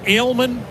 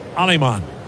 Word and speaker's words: Alemán: He spelled the last name A-L-E-M-A-N. Looks like Ailman, but it's pronounced Alemán.